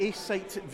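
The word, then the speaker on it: AceSight: The A-Sight VR.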